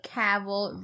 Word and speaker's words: Cavill: Henry Cavill rhymes with gavel.